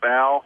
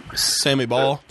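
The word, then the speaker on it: Baugh: Is it Bow? Is that how you pronounce the name? – Sammy Ball.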